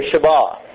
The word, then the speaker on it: Shabbat: Our transliteration is Sabbath. It would be pronounced in Hebrew, Shabbat.